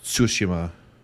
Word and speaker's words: Tsushima: Just put the T and S like flow into each other, like Tsushima. – Tsushima.